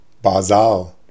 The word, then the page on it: foire: /fwaʁ/; /fwɑʁ/